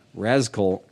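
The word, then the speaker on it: Razkull: So if you're looking this shit up, it is R-A-Z as in Z, Rascal, K-U-L-L. So it's like skull, Rascal.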